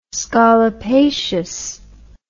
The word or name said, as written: scolopaceous